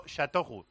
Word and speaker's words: Châteauroux: Bueno, Châteauroux es la forma correcta de decirlo.